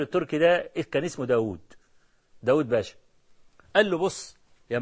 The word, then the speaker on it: داود: قال له سرق واو مولانا، ما هو سي داود احنا بنكتبها بواو واحدة وهي بتتنطق أو بالأصل تتنطق باتنين واو، لما تقول داود دال ألف واو واو دال، طاووس طا ألف واو واو سين ها، لكن احنا بنكتبها داود طاووس بواو واحده.